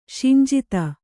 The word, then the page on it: ಶಿಂಜಿತ: kannada